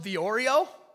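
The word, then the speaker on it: theoreo (θεωρέω): And I love it because it's spelled the Oreo. T-H-E-O-R-E-O, and I love Oreos, and so this may be my favorite Greek word ever, but it's pronounced theoreo, so say theoreo.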